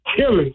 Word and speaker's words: Tillis: Tillis. – That's him. – Yeah, did I say it right? – Yep, sure did.